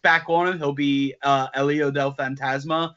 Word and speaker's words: El Hijo del Fantasma: Group B is Akira Tozawa, El Hijo del Fantasma.